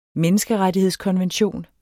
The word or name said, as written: menneskerettighedskonvention